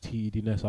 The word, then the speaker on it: TEDness: T.E. is short for temper. Okay. So I used to be called temper, bro. MC temper or temper, T.E.M.P.R. I used to spell it. And D.N.S. is just a play on my surname, Dennis. – Okay. – My name is Dennis, so T.E. was short for temper. And then it was TEDness.